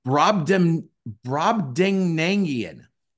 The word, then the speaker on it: Brobdingnagian: It's Brobdingnagian. And what it means is it's a giant. Apparently in Gulliver's Travels, there was a land called Brobdingnag, and that's where the giants live. So to be Brobdingnagian, here, computer say it.